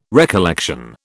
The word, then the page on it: recollection: British English